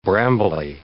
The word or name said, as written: brambly